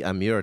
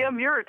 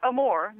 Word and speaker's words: Amur: The Amur tiger, Amur Highland. – The Amur tiger. Not Amur, not the love tiger, but Amur tiger.